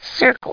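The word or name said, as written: circle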